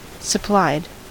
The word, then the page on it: supplied: American English